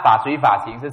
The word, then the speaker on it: 法随法行: 那法随法行是什么呢？一般我们把这个读都会把它读成四个字连在一起，对不对？其实它是拆开的哦，在梵文里面它是法一个，随法行三个字连在一起，它是这样的，它是两组东西来的。